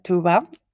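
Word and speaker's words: Tuğba: We have with us Tuba. She has a silent G in between her name.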